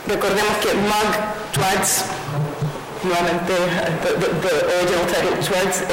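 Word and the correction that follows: Twarz: am I also killing the pronunciation? – Twas.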